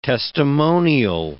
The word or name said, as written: testimonial